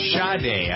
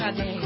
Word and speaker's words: Sade: Sade, S-A-E-E, is one woman, right? – Yeah. – Yeah. And it's pronounced Sade, I believe. – Sade. – Sade, even though it's spelled S-A-D-E, there's no H in it.